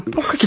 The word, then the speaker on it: 포크송: 그러니까 약간 뭐라 그럴까 폭송이라고 폭 아, 포크송. – 기타로. – 아, 포크소. – 예, 폭. 아, 그 발음 그 발음 트 발음 뒤 발음 잘 안 돼요.